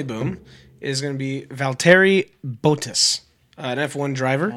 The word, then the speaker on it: Valtteri Bottas: It's Valtteri Bottas.